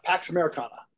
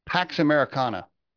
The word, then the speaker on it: Pax Americana: How do you say it? Pax, what do you say? – Pax Americana. – Pax Americana.